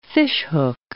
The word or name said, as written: fishhook